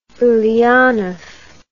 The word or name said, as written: Ulyanov